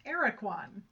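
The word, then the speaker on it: Aracuan: And so all the pronunciations I'm seeing are saying it's pronounced Araquan.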